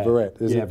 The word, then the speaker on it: Verrett: Verrett. That's how you pronounce it, Verrett.